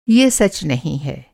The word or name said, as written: सच